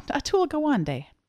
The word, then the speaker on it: Atul Gawande: Oh, Atul Gawande.